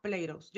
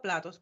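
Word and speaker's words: Plato's: Por lo menos aquí en Estados Unidos está la, no sé si está en todos los estados, que se llama Pleiros. Yo le digo platos porque se escribe así platos, pero se dice Pleiros.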